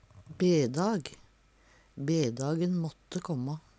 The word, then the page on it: dæ kjæm ein bedag: Numedalsmål